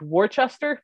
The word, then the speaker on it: Worcester: I'm going to go with Worcester because that's how it's spelled.